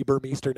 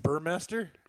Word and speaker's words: Burmester: Yeah, well, that's what I, he's always going to be Burmeister now. But it looks like it's Burmester. – Okay, let's get.